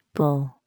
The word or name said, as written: bull